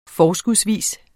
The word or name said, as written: forskudsvis